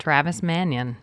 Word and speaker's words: Travis Mannion: Today we're talking Travis Mannion Foundation. Am I pronouncing that right? – You are correct. Travis Mannion.